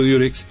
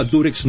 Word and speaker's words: Durex: Así se llama Durex, no Durex. Lo que pasa es que nosotros pronunciamos, al decir Durex, uh-huh. pronunciamos la U como se pronuncia en inglés, U, okay.